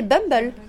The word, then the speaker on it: Bumble: Ouais, Comment je tu prononces Bumble Ouais, c'est Bumble.